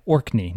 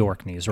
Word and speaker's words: Orkney: Yeah, so I was at a think tank in D.C., and I met a guy from, hang on, I'm going to say it correctly, Orkney, not the Orkneys, right? It's Orkney.